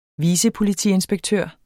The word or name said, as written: vicepolitiinspektør